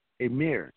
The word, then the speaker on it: Emir: Emir. – Can you spell that, sir? – E-M-I-R. – E-M-I-R.